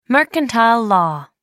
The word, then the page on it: mercantile law: American English